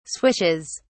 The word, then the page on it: swishes: British English